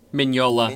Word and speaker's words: Mignola: Mignola? – Mignola.